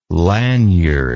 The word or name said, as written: laniard